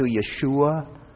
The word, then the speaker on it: Yeshua: It's the transliterated version of the Hebrew word that, to the best of my pronunciation ability, sounds something close to Yeshua.